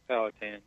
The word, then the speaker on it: Powhatan: Is it Powhatan, Virginia? Is that how you pronounce it? – It is Powhatan, yes.